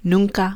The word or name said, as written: nunca